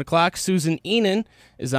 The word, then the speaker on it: Susan Enan: Tell me about Susan Enan a little bit. Am I saying that right? – I think so. Enan.